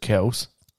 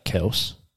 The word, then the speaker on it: Kelce: I've found out today on my lunch break we've been saying Travis's name wrong. – Is it Kels? – Correct. So, Papa Kels. – Papa Kells. – Got sick of having to correct people. So he has just been rolling with Kelce.